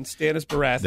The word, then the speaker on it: Baratheon: Stannis Baratheon.